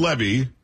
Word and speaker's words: Levy: So Dan Levy, because it was Eugene Levy's son, that's how they pronounce it.